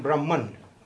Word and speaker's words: Brahma: Now, you know the distinction between Brahma and Brahman, okay? Brahma, with a long A at the end, is a mythic character.